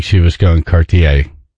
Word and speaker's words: Cartier: Near Cartier. Is that how you say it? Cartier? – Cartier, yeah. – I think it's Cartier.